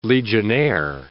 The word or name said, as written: legionnaire